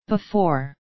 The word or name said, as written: before